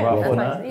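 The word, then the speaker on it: Rob49: I like Rob 4-9. I'm saying it correctly? – Yeah, Rob 4-9.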